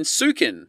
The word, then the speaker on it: Sukin: For a few years now, I've owned shares in Sukin.